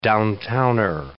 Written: downtowner

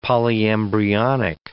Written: polyembryonic

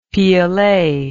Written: piolet

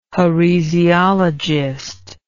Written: heresiologist

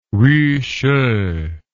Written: wuxi

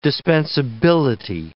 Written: dispensability

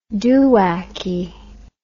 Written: doowacky

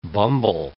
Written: bumble

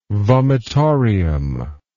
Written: vomitorium

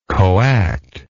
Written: coactor

